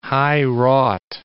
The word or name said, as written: high-wrought